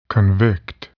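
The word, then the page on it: convict: verb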